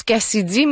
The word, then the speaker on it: KCIDY: Parlons maintenant de l'artiste Cassidy, qui se prononce Cassidy, mais ça s'écrit K-C-I-D-Y.